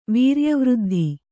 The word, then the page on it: ವೀರ್ಯವೃದ್ಧಿ: Kannada